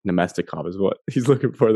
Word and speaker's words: Namestnikov: The name Evan meant to say there, I believe, was I'll help you pronunciation wise. It was Namestnikov.